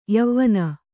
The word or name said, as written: ಯವನಿ